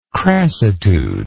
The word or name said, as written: crassitude